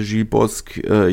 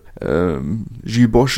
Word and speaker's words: Gilles Bosch: Entsprechend die halbe Startformation schon aussortiert und vor dem Absprung Gilles Bosch unter anderem, beziehungsweise Gilles Bosque, je nachdem, wie man es aussprechen möchte, Gilles Bosch ist korrekt, oder hat er mir persönlich so bestätigt, dass Gilles Bosch korrekt ist. Allerdings muss man auch sagen, dass Bosch immer noch ein abwertender Begriff für sogenannte Deutsche ist. Entsprechend bevorzugen viele Franzosen die Aussprache Bosque.